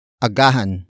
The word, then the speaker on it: agahan: Word for word it's agahan, mo, ang, pagtulog, agahan, mo, ang, pagtulog, agahan, mo, ang, pagtulog.